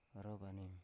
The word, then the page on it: orovanim: Biak